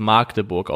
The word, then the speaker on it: Magdeburg: Mir haben mehrere ähm, FCM-Fans geschrieben, dass ich bitte darauf hinweisen soll, dass es Magdeburg ausgesprochen wird.